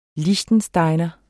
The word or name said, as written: liechtensteiner